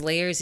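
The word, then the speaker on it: L@yerz: My name is Layers. You can look me up. It is spelled L with the at sign Y-E-R-Z.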